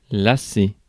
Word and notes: bore: American English

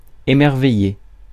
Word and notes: émerveiller: France; [e.mɛʁ.ve.je]

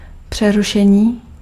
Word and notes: interruption: France; /ɛ̃.tɛ.ʁyp.sjɔ̃/

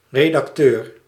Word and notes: editor: /ˈɛd.ɪ.tə(ɹ)/

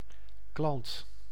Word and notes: klant: [klɑnt]

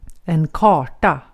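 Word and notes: karta: IPA: /ˈkɑːrta/; IPA: [ˈkʰɑːʈa]; IPA: /kɑːʈa/; Swedish

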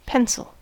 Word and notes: crayon: France; /kʁɛ.jɔ̃/

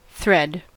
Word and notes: thread: [θrɛd]; /θɹɛd/

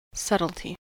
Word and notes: subtlety: American English

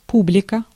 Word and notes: публика: /ˈpublʲɪkə/; publika

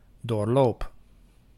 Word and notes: doorloop: /doːrˈloːp/; /ˈdoːrˌloːp/

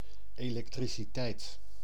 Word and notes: électricité: France; /e.lɛk.tʁi.si.te/

